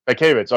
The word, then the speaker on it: Mankiewicz: It's written and directed by Joseph Mankiewicz, if that's how you pronounce it. – Mankiewicz, okay, then.